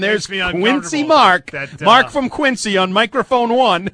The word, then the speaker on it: Quincy: I don't know, but it's Quincy, Massachusetts. – I didn't know it had a Z in it. – Yeah, it does. It's pronounced that way.